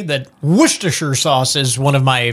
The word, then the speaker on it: Worcestershire: 1 tablespoon of molasses, 2 tablespoons of Worcestershire sauce. I always say that wrong. – Worcestershire sauce.